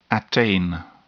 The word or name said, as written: attain